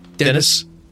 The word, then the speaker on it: Denis: And I said, his name wrong there, Will. – What is it, Denis? – Dennis.